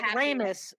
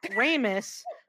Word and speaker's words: Remus: So Remus was like, Remus, sorry, I mispronounced his name. Remus.